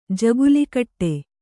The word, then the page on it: ಜಗುಲಿಕಟ್ಟೆ: Kannada